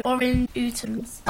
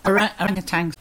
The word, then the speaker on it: orangutans: Larger apes are chimpanzees, gorilla from Africa, and orangutans. – Orangutans. – Right, that's orangutans. – Yeah, that's how you pronounce it.